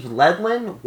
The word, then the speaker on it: Yedlin: Steve Yedlin, Y E D L I N.